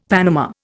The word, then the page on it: panama: Indian